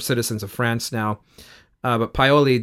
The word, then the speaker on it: Paoli: Where he does not have a pleasant reunion is with Paola. Am I saying that right? – Paoli, excuse me. – Paoli, I think.